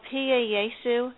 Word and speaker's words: Pie Jesu: It's called Pia Yesu. It's on YouTube. It's spelled P-I-E-J-E-S-U, which means Sweet Jesus.